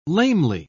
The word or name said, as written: lamely